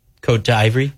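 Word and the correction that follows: Côte d'Ivoire: Cote d'Ivoire.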